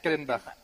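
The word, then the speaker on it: Callenbach: Et j'ai lu récemment Écotopia de Ernest Kallenbach. – Voilà, merci, tu le prononces beaucoup mieux que moi en plus.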